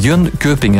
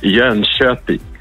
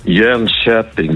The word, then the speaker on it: Jönköping: Het is niet Jönköping, hè? Dat is totaal verkeerd. Hè? Wat moeten we dan wel zeggen? – Ja, het is eigenlijk Jönköping. Jönköping. Dus de K voor de umlaut wordt heel zacht uitgesproken in Zweden.